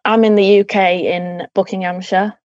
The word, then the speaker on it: Buckinghamshire: Yeah, Buckinghamshire.